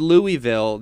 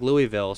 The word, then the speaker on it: Louisville: Last week on this very program, when I introduced the game, I said Louisville. That was my pronunciation of the school. That's typically how I've heard broadcasters say it. It's how I got used to it. But my co-host Jackson, who's, you know, as from the South as one could be, he gets on to my case. It's Louisville. He can't say it like Louisville.